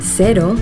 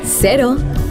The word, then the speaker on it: cero: In Yucatan, the local Mexican surfers pronounce cero, cero. During the warm summer evenings in bars around the coast of Nayarit, you'll hear them say cero. In New Zealand, zero alcohol Corona cero is pronounced cero.